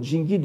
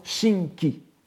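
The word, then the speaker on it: Jingi (神祇): Les plus pointilleux d'entre vous auront remarqué, comme dans le cas de Shouka Jōge tout à l'heure, n'est-ce pas, qu'il s'agissait, dans le cas de Jingi, d'une prononciation dite Go-on. La prononciation Ka-on, la prononciation donc modelée sur celle des Tang, étant Shinki, ou à la rigueur Shingi.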